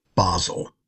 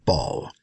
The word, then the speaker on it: Basel: And so Martin summons this council, this next council, which is going to be held in Basel, right? Or the French pronunciation, ball.